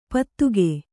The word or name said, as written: ಪತ್ತುಗೆ